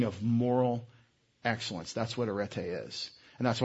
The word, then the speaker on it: Areté: And I've had questions, why is it called Camp Areté, A-R-E-T-E? That's not Areté, it's Areté, because the epsilon says eh, as we pronounce Erasmus in Greek, if you're interested.